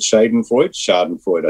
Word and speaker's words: Schadenfreude: And not only that, a celebration to a degree, or a sort of, how do you say that word, Schadenfreude, I think is the correct pronunciation, when anybody else got caught doing it.